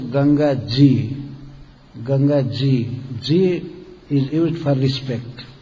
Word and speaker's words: Ganga Ji: Ganga Ji, Ganga Ji, but they could not pronounce properly, so they Ganjis, Ganjis, they were saying like this. But actually the real word is Ganga Ji.